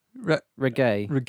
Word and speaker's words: Raguet: Louise Ragu. – No. – Ragay? I'll say Louise Spell it! – R-A-G-U-E-T. Regeu.